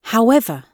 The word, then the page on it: however: British English